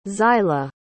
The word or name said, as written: xyla